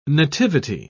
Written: nativity

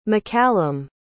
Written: mccallum

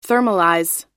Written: thermalize

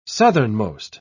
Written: southernmost

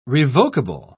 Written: revokable

